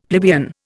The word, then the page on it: plebeian: Indian